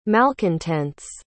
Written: malcontents

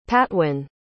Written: patwin

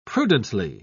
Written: prudently

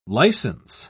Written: license

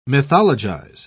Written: mythologize

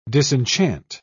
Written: disenchant

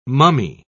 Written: mummy

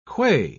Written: qua

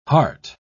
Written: hart

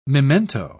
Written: memento

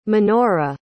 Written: menora

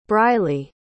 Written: Brileigh

